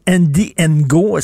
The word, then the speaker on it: Andy Ngo: Et là, tu vas nous parler d'un personnage très, très controversé, Andy Ngo.